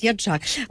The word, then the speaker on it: Яджак: Галина Яджак, я сподіваюся, наголос правильно, якщо неправильно, зараз пані Галину попрошу виправити мене, експертка програми «Освіта, формація і захист дітей Карітасу України». Пані Галино, вітаю вас. – Вітаю. – Наголос прізвище правильно? Чи не зовсім? – Яджак. – Я вибачаюсь, Яджак.